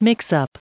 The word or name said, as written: mix-up